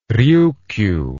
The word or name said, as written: ryukyu